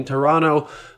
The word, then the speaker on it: Toronto: The fact that you don't pronounce the second T in Toronto was a huge surprise for me, because you do that in Latvia.